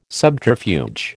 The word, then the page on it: subterfuge: American English